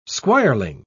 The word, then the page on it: squireling: British English